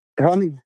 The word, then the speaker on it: Hørning: Øh, jeg ved ikke, om jeg udtaler det rigtigt, men er det Hørning? – Hørning. Hørning, ja tak.